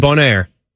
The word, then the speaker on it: Bonaire: Bonaire, and is that how you say it? Bonaire. – That sounds good. – And Bonaire.